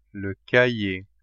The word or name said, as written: cahier